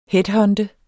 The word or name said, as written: headhunter